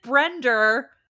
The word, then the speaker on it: Brenda: He pronounces it Brender. – That sounds like Brender, because he's a New Yorker.